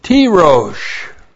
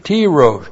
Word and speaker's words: Tirosh: The second one is Tirosh. T long I R long O S H. Tirosh. Tirosh is new wine.